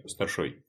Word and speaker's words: старшой: «Ну что, решай, старшой?» С ударением на последнем слоге спросил Степанов.